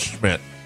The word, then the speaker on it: Szmyt: It says pronounced Schmidt. – S-Z-M-Y-T. Yeah, it is not spelled Schmidt.